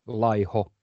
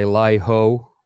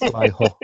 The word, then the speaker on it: Laiho: But usually they say Laiho, which just sounds a bit weird. But but yeah, it's Laiho.